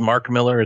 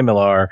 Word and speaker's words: Mark Millar: The man's name is Mark Miller. It's pronounced the exact same as Frank Miller. And people who say Millar, well, they go to a special, special kind of hell.